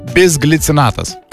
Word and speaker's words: Bisglicinatas: Po įtemptų žaidimo svarbu pasirūpinti organizmo atsistatymu, beje, čia tas žodis, kurio aš visą laiką nemoku ištarti teisingai. Bisglicinatas. – Ta, o, puikiai, puikiai.